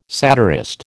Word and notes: satirist: American English

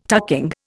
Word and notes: tucking: Indian

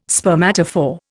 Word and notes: spermatophore: Indian

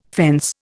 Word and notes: fence: Indian

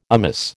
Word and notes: amiss: American English

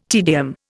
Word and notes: tedium: Indian

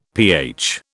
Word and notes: pH: British English

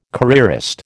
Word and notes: careerist: American English

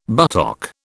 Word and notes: buttock: British English

